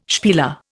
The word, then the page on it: spieler: Indian